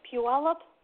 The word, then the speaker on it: Puyallup: Misty is missing from Puyallup, Washington.